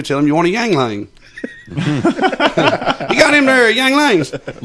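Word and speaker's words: Yuengling: One of my favorite beers to order is a, and I'm not going to say it right now, is a Yingling. – Yingling.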